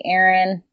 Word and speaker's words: Erynne: Y'all can find me on Instagram, essentially Erin. Name is spelled redonkulously, E-R-Y-N-N-E.